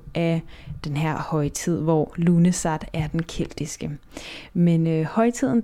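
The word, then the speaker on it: Lughnasadh: Ja, det udtales Lunesat, men det staves på en meget forvirrende måde, og ja, du kan se, hvordan det staves her i podcastens titel.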